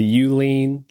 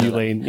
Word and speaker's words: Youlean: But it's the Ulean loudness meter. – It's a funny name, but it's spelled Y-O-U. L-E-A-N.